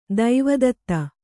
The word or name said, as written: ದೇವದತ್ತ